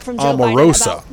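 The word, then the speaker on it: Omarosa: I want to go back because my producer informed me that last segment I said Aramosa. It's pronounced Amorosa.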